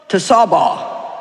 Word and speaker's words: Tsebaoth: And then the last one is Jehovah Tsebaoth, and it's spelled T-S-E-B-A-O-T-H, and it means the Lord of hosts.